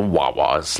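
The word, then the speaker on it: Hoa Hoa's: The band name is spelt H-O-A, H-O-A apostrophe S, but it's pronounced the Wawas, like the Vietnamese word for flower twice.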